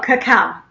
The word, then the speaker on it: cacao: And with the, is it cacao? How do you spell that? It's not cocoa. It's the original one. – Oh, cacao. Yep.